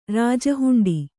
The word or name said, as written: ರಾಜಹುಂಡಿ